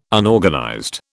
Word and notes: Unorganized: British English

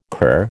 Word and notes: cur: American English

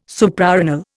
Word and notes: Suprarenal: Indian

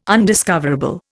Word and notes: undiscoverable: Indian

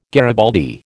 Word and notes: garibaldi: American English

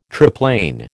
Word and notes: triplane: American English

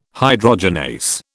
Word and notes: hydrogenase: British English

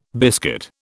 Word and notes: biscuit: British English

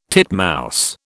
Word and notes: titmouse: British English

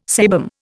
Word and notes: sebum: Indian

